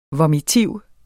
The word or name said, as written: vomitiv